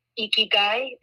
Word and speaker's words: ikigai: It's pronounced ikigai. It's I-K-I-G-A-I.